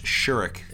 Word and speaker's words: Shorrock: But the main song is American Flyers by Glenn Shorrock. And it's pronounced Shurik. – Sorry. – Because he's Australian and that's how they pronounce it there.